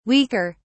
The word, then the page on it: weaker: British English